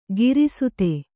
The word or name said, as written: ಗ್ರಸ್ತ